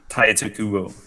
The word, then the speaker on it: Tite Kubo: Taito Kubo. Yep. – Is that how you say it? – Taito. – Yep, Taito. – Yeah, Taito. – It's just spelled wrong.